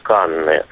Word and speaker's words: Канн: Андрей, это ведь вы у нас закрепили традицию говорить не «Канны», как мы говорили прежде, а «Канн».